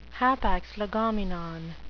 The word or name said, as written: hapax legomenon